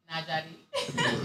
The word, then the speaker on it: Nallari: I'm Nagari. – Uh-huh. – Oh. Say it slower for the audience, please. It took you five times to get it. – It's pronounced Nagari. – That's a beautiful name. – Spelled differently, though. – N-A-L-L-A-R-I.